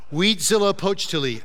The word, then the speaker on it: Huitzilopochtli: Yeah, I practiced that a bunch. I did practice that. Weedzilla Pochtili. I said it right ish.